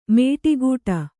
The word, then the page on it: ಮೇಟಿಗೂಟ: Kannada